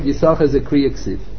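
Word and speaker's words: Yissachar: Throughout the whole Tanakh, it's pronounced Yisachar as if there were only one. It's spelled with two shins. They pronounce it as if there were only one sin, as if there were only one shin.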